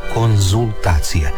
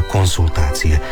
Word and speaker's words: konzultacije: A evo slučaj u kojem se često izgovara i piše s, a trebalo bi se reći z. I doktorandi i studenti prije obrane doktorata ili prije ispita idu svojim profesorima na konzultacije. Ne idu na konsultacije.